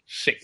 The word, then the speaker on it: Sic: It's the S-I-C is the spelling. Sick.